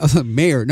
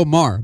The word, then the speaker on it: Maher: Bill Maher, and by the way, I really need to just misspell the last name so I won't say it incorrectly. – You have to spell it wrong to say it right. – Right, it just for Mayor. No, Bill Maher. Maher.